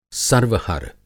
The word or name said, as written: सर्वहर